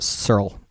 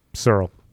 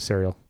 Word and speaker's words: C3RL: It's pronounced Searle. S-E-R-L. – Searle. So I'm going to quit calling it Serial.